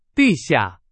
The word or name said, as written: 地下